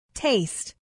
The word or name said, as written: taste